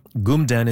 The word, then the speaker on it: kmtn: Gumden is spelled K-M-T-N. It means mountain in Mi'kmaq.